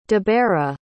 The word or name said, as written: debera